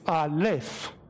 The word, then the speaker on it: Aleph: It's Aleph. It's an entire word. It's not just A. That's a vowel sound. It's Aleph, okay?